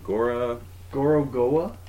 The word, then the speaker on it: Gorogoa: Gorogoa? – There you go.